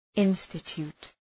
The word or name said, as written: institute